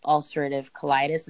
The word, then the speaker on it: ulcerative colitis: You were 19 when you were diagnosed with ulcerative colitis.